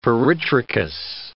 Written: peritrichous

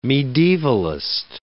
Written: medievalist

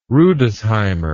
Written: rudesheimer

